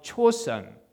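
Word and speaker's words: Chosun (조선): While in seminary, Underwood heard of Chosun. It sounds like, I mean, spelled like a chosen, but Chosun, a tiny country in the Far East for the first time.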